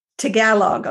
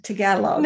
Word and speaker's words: Tagalog: Tagalog. Okay, I didn't want to say it wrong. Tagalog.